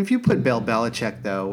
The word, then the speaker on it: Belichick: I'm a Bill Belichick fan, but not a Bill Belichick fan. – Oh, my God. – How do you pronounce his name? – What's happening? Now I'm too close. Yes. I'm popping. – You're popping. – Is it Belichick? – Belichick.